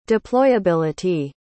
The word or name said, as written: deployability